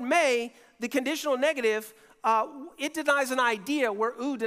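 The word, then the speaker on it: may (μή): The word that Jesus uses is spelled M-E, but it's pronounced may.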